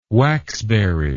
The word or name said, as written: waxberry